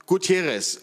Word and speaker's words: Gutiérrez: Und wie ich sagte schon, da sind Magali, Ricardo, äh, Guterres, ist richtig? Gutierrez, okay, richtig ausgesprochen.